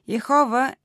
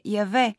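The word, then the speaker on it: Яхве: Освен това, Яхова е едно погрешно произношение на четирите съгласни, с които в еврейската версия на Библията се пише непроизносимото име на Бог. Учените вече от доста време доказаха, че правилното произношение на тези четири съгласни е Яве.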